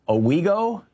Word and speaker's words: Owego: Richard in Owego, New York.